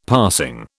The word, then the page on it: passing: British English